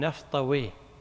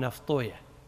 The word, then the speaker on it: نفطويه: اللغويون يقول راهويه المحدثون يقولون راهوية، اللغويون يقولون سيبويه، المحدثون يقولون سيبويه، اللغويون يقولون نفطويه، المحدثون يقولون نفطوية. ليش؟ لأنهم قرأوا في بعض الكتب أن من أسماء الشيطان ويه، فيكرهون نطق الاسم على اسمه فغيروه فخلوا راهوية سيبوية.